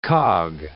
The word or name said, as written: cog